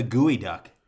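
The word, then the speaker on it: geoduck: On a side note, that reminds me, for our listeners, look up the gooey duck. It's spelled G-E-O-D-U-C-K, I think.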